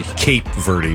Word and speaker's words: Cape Verde: Cape Verde, by the way. Cape Verde. You've said it Cape Verde. – You've said it wrong twice.